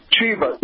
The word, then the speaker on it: Shiva: But just recently, a doctor, I believe his name was Dr. Shavi, and if I pronounce that wrong, you can correct me. But Shiva. – Yeah, I knew that. I always say Shavi, but Shiva.